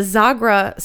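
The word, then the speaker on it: Xagħra: In Malta, the Zagra Circle, that's spelled with an X. X-A-G-H-R-A. – Where's Malta? – Malta is a island off the coast.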